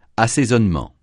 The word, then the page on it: assaisonnement: francais